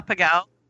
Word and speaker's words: Pegau: Close enough. Yeah, that's okay. Pegau, yeah. Pegau. It's actually, yeah, it's German.